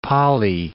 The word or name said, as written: Pali